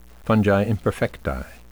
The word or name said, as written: fungi imperfecti